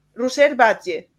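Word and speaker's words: Roser Batlle: Lleva 26 años como docente y en 2016 descubrió el aprendizaje servicio perdón, de la mano de Ruser Badge. ¿Lo he dicho bien? Sí. – Muy bueno, bien.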